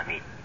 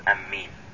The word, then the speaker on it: آمين: والمشهور في نطقها وفي قراءتها أنها آمين بمد الهمز وتخفيف الميم مع كسرها آمين، وقرئ شاذا آمين من غير مد، وأيضا بتشديد الميم أمين، ولكن هذا شاذ.